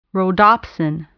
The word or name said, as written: rhodopsin